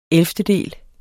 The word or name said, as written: ellevtedel